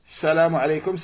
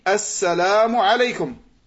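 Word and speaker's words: assalamu alaykum (السلام عليكم): Beaucoup de personnes, quand ils font salam, ils disent salamu alaykum, salamu alaykum. Salam, on dit assalamu alaykum. Assalamu alaykum. C'est ça la prononciation correcte.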